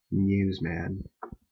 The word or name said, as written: newsman